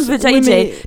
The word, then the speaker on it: vajayjay: Mais qu'elle prononce, comme vajayjay. mais...